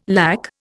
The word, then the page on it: lack: Indian